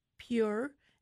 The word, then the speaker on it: Pjur: One of these is called Pure, and that's spelled P-J-U-R.